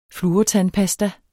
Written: fluortandpasta

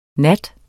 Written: nat

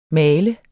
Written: maler

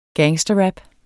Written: gangsterrap

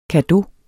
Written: cadeau

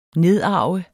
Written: nedarve

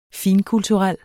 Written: finkulturel

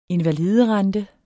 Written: invaliderente

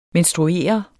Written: menstruere

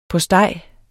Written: postej

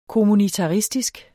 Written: kommunitaristisk

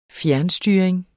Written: fjernstyring

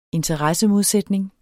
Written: interessemodsætning

